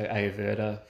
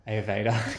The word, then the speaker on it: Ayurveda: So Ayurveda, for example. – Ayurveda. – I have a pronunciation problem. – It's fine. I just have to correct you every time.